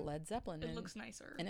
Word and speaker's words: Led Zeppelin: and then they ended up dropping the A in lead because they were afraid people would pronounce it as lead. – Yeah, lead, lead Zeppelin.